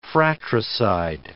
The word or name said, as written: fratricide